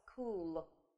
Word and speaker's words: school: Это слово school. Школа. Куда ж мы без нее, да? Без нее никак. Поэтому нужно запомнить, что слово школа пишется вот так. School. Мы здесь видим с вами сочетание CH, но оно читаться как Ч не будет. Оно будет читаться как К. Вот так в транскрипции будет. Это исключение.